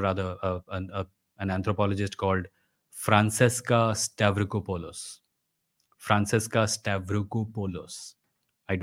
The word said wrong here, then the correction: Stavrakopoulou: Not Polos, Polu. Francesca Stavrakopoulou.